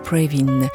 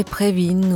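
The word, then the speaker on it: Previn: Il est donc assez logique de prononcer son nom à l'allemande, Prévin. André Prévin ou André Prévin,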